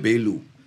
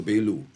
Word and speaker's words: Belu: Coincidentally, he's named by the Blue Hill Road, not Blue Hill Road, B-L-U-E, but Belu, Mr. Belu. – Okay. – That's how you pronounce it, right? – It's Belu.